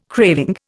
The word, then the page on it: craving: Indian